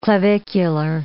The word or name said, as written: clavicular